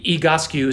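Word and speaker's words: Egoscue: EGOSQUE is how you pronounce it. It stands for EGO, like ego, SQUE.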